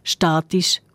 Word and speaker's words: statisch: Stativ auf der zweiten Silbe, statisch auf der ersten.